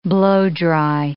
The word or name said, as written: blow-dry